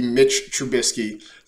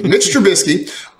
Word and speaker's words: Trubisky: But I think per Aditi Kinkabwala, he has said he prefers to be Mitch Trubisky. So let me adjust this here in our rundown so I say this properly. Mitch Trubisky, two-year deal, $14.25 million.